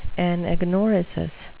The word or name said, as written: anagnorisis